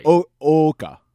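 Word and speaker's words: Ōoka: It's O-O-K-A. – Actually, it's Oka. – Yeah. – Yeah, like double O's in Japanese are O.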